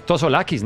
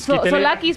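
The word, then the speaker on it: Tzolakis: Es Tosolakis, ¿no? Sí, Solakis. – Solakis, porque la T es muda.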